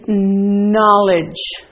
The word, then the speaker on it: knowledge: This one is knowledge. Knowledge. The K is silent.